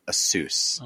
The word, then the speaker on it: Asus: I was told by the company it's pronounced Asus.